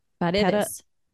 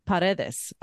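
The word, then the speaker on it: Paredez: Selena biographer Deborah Paredes, it's a name, but I feel like Paredes. Yes. – How do you spell it? – P-A-R-E with one of the little things over the top. D-E-S. D-E-Z, sorry. – Paredes. – Paredes. Okay.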